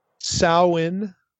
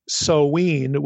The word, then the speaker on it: Samhain: But according to a linguistic website that I found on the NC State website, the larger website, this term is pronounced either Samhain, like ow, the O-W in cow, or Samhain with the O-W like glow.